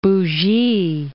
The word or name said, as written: Bougie